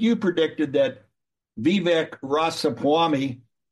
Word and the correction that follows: Ramaswamy: Vivek Ramaswamy.